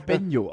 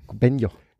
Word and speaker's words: Banjo: Das wird ja auch Benjo ausgesprochen. – Benjo.